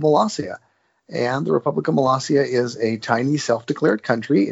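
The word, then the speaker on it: Molossia: Malaysia. Have I pronounced it correctly? – It's actually Malaysia.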